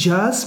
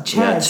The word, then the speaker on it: Chaz: En dat is, ik weet niet of dat jij het kent, ik denk dat ik het zo ook moet uitspreken, is Chaz. Het is eigenlijk niet jazz, maar Chess. C-H-A-Z. Ja. – Niet J-A-Z.